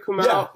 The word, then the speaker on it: Kumail: Am I saying that right? Kumail? – Yeah.